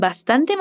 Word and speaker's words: bastante: Y el chico quiere su sándwich con bastante mayonesa. Repite. Con bastante mayonesa. Ahora vamos a ver su pronunciación. Facundo también aspira la S al decir bastante.